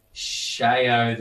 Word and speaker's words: Xiaomi: Technically, the first syllable is more Shao than show. – Right. – So the difference is fairly subtle to most American ears.